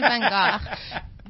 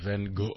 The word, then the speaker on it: Van Gogh: Say it like you were Dutch. – Van Gogh. – Van Gogh.